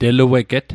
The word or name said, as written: telueget